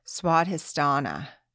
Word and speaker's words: Svadhisthana: And this one is pronounced Svadhisthana, sexuality and pleasure.